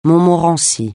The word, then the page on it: montmorency: francais